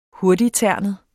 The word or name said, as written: hurtigternet